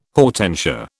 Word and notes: hortensia: British English

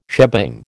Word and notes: shebang: American English

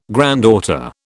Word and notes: granddaughter: British English

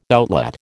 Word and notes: outlet: American English

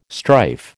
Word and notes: strife: American English

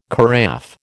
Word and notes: carafe: American English

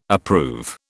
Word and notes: approve: British English

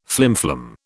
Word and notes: flimflam: British English

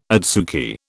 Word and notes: adsuki: British English

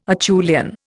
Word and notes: Acheulian: Indian

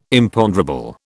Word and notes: Imponderable: British English

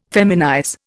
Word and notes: feminize: Indian

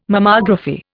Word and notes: Mammography: Indian

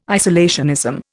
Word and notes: isolationism: Indian

